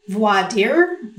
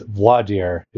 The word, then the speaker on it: voir dire: I have learned, because I think it all is learned behavior, it's voir dire is the way I learned it. However, I do have a penchant toward leaning to the Texas pronunciation I've heard, which is voir dire.